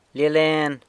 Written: Lia Len

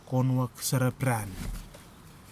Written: konuwak sarapiran

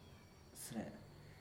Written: sre

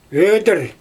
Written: ödIr

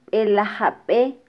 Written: belajabe

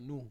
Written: nu

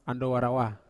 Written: andowa rawa

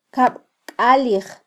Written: kabkalij

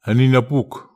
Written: eni napuk

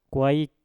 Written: kwaik

